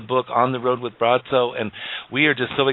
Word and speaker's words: Braco: Bratso, but it's spelled Bratso. So you look at his name and you would say Braco, I think in English, but Croatian, it's Bratso.